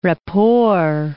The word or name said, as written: rapport